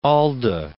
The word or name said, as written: Alda